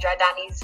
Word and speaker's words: Jardani: I'm Jordani. You can follow me at Jordani's View. – Come on. – On Instagram. – Hey. – That's spelled like John Wick's first name. Jordani.